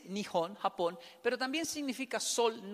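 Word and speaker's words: Nihon (日本): Nihon, así se pronuncia, Nihon, tiene trazos curvos el de abajo.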